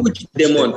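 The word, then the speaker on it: Puigdemont: Că uh, soția lui Pui, nu știu cum să pronunț, Puici de Mont sau cum se Puci zice? de Mont. Puici de Mont, așa, bun.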